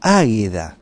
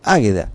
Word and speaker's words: Águeda: Águeda, con acento en la y todo, Águeda.